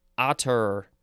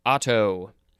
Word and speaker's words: attar: Um, we're going to pick that word that I had a hard time pronouncing otter or otter or otto. Uh, it is spelled A T T A R.